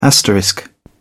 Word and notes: asterisk: British English

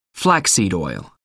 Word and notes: flaxseed oil: American English

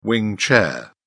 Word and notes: wing chair: British English

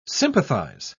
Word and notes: sympathise: British English

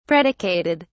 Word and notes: predicated: British English

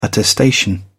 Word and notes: attestation: British English

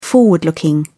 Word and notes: forward looking: British English

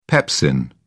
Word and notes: pepsin: British English

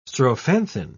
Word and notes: strophanthin: British English

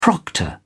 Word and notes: proctor: British English

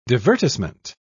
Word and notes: divertissement: British English

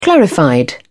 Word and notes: clarified: British English

